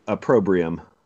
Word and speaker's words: Opprobrium: But I think it's Opprobrium. Is that how you say it? – Opprobrium.